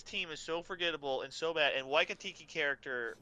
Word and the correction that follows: Taika Waititi: How do you say it? – Taika Waititi.